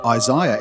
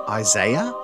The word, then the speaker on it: Isaiah: Wait, Isaiah, isn't it Isaiah? – Yes, our American cousins sadly mispronounce a key book of the Bible, the book of Isaiah, not the book of Isaiah.